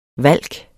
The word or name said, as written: valk